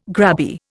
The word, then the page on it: grabby: Indian